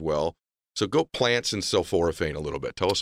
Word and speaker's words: sulforaphane: So I'm learning about sulforaphane. Did I say that correctly? – Yeah, sulforaphane.